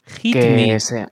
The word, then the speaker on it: HITM3: Hit Me, como Hit Pégame. Me. – Sí, o sea, se escribe HITM3, todo junto.